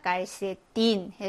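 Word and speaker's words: calcetín: 그러니까 깔세딘 해서 세따 혹은 에스로 발음이 됩니다.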